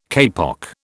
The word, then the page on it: kapok: British English